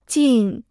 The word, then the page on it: 尽: jìn